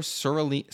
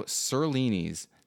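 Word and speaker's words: Zurlini: The movie was Valerio Zerlini's 1968 film. Did I say that right this time? – It's a Z, not an S. Zerlini.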